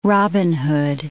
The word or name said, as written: Robin Hood